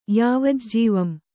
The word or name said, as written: ಯಾವಜ್ಜೀವಂ